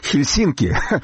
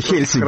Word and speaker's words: Хельсинки: Меня моя жена Марианна, финка, научила, что в финских фамилиях, в финских названиях всегда нужно ставить ударение на первом слоге. Это то, чего американские корреспонденты никак не могут выучить. У них все время Хельсинки вместо Хельсинки.